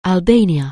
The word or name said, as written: Albania